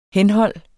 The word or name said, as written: henhold